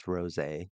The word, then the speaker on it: rosé: So to go with this cocktail, to go with this story, I have a cocktail and I understand that rosé is pronounced rosé, but it's spelled as rose.